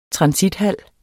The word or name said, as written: transithal